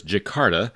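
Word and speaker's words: Djakarta: Well, the answer is Jakarta. That's spelled the old way, starting with a silent D, D-J, A-K-A-R-T-A, and you drop three letters, you get Dakar, the capital of Senegal.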